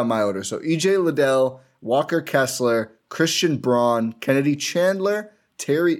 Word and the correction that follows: Braun: And actually, sorry, it's Christian Brown, not Braun. It's spelled Braun, pronounced Brown.